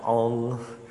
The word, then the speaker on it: Ingres: It's pronounced Ing. It's Ingres.